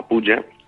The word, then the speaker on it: puja: La puja, no sé si lo pronuncio bien, la puja, los yagyas y los samskaras, ¿no? ¿Lo he pronunciado bien, puja? – Sí, sí. Puja, que se refiere a la adoración en el templo, la adoración para la deidad.